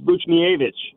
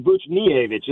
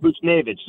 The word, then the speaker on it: Buchnevich: Hey, and I actually asked him recently, I said, how do you pronounce it? He said it's Buchnevich. So it's actually Buchnevich. It's not Buchnevich. So, like, nobody's saying it right, to be honest.